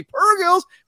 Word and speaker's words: purrgils: We got purrgils, And that's how you say it. No hard G. purrgils.